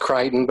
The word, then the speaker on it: Crichton: It's fun to talk about him because it's it's pronounced Crichton, but it doesn't look that way when you see it spelled out.